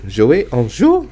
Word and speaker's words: Jouets en Jeu: Jouets en Jeu.